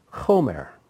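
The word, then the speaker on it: chomer (חֹמֶר): the word there for tar is the word chomer. It's spelled like this. Let's see, is there a vav in that? No, there's no vav. Ket mem resh. Chomer. That's how you would spell it in English. So chomer means tar. But if you change the pronunciation, don't change the spelling, just change pronunciation to chemer. Chemer means wine.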